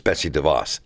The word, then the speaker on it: Betsy DeVos: And I mispronounced her name. I always start her name. It's Betsy DeVos. Sorry.